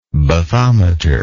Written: bathometer

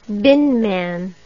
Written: binman

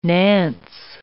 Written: nance

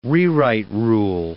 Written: rewrite rule

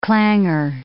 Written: clanger

